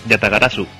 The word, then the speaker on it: Yatagarasu: Y esta semana, en el juego de la quincena, tenemos el, a ver si lo digo bien, Yatagarasu. – Pues sí, sí, lo has dicho bien, Yatagarasu.